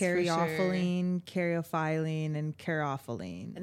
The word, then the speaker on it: caryophyllene: Like, yesterday we were doing labels for jars, and he was writing all those down, and I was like, carophyllene, and he's like, how it was pronounced. – Carophyllene. – There it is. – Caryophyllene. – Yeah. And Caryophyllene.